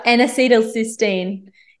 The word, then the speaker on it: N-acetylcysteine: N-acetylcysteine.